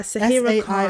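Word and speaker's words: Saira Khan: Yeah, Sahira Khan. – S-A-I-R-A Khan. – Sahira Khan, I feel like is how you say it.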